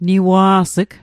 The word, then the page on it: niwa's'g: Mi'gmaq